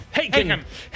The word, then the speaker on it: Haken: The Endless Knot di Haken. L'ho detto giusto? – Giustissimo, Haken. Giustissimo. – Haken.